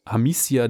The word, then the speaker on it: Amicia: Es geht eine mittelalterliche Geschichte, es ist ein Action-Adventure, also ein Abenteuerspiel und man spielt eine junge Frau, Amicia Derün, die zusammen mit ihrem Vater Robert, ihre Mutter Beatrice und ihrem jüngeren Bruder Hugo, das habe ich jetzt alles richtig ausgesprochen, Französisch, weil ich habe es ja auch gespielt, als Adlige, ähm, als Adlige auf dem Land leben.